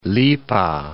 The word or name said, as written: lipa